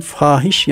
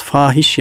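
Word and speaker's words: fâhiş: Çünkü Arapçadan, Farsçadan dilimize giren kelimelerin mahiyeti bilinmediği için, aksan dediğimiz şapkalarda kaldırıldığı için fahiş yanlışlar yapılıyor. Bakınız fahiş yanlışlar dedim. Fahiş diyebilirdim değil mi? Çünkü orada yani F'den sonra elif var, uzatılacak.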